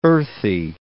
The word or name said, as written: earthy